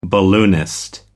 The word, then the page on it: balloonist: American English